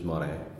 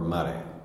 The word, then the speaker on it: Morin: It's based on my name, my last name, which is Morin, or Mare, depending on, it's, in my family, it's spelled two different ways.